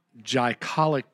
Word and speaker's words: glycolic: Kira Labs founded in 2003 with a debut line of highly effective skincare products, including natural cleansers, glycolic peels.